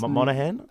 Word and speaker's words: Monaghan: It's great because let's not forget that Michelle Monaghan, if that's how you pronounce her name, because I've heard it pronounced Monaghan. – I've heard it pronounced Monaghan, Monaghan, and Mahogany.